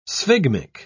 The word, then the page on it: sphygmic: British English